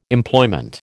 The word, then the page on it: Employment: American English